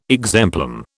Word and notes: exemplum: British English

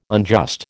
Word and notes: unjust: American English

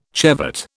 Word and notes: chevet: British English

